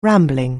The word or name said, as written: rambling